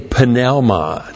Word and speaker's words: pneuma: There are no silent letters in Greek. You pronounce every letter. Pneuma.